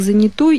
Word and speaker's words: занятой: Вы человек занятой или занятый? Как правильно произносить? – Только занятой, и форма занятой является просторечной.